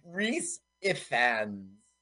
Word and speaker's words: Rhys Ifans: So it's R-H-Y-S. – It's Rhys Owls, right? – Rhys, R-H-Y-S. And then it's I-F-A-N-S. Rhys Ifans.